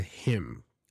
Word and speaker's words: hymn: It's a hymn. And it sounds just like him when you talk to him, for example. But it's H-Y-M-N. M-N, and that N is silent. So that combination, you would have a silent N.